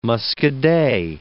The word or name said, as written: muscadet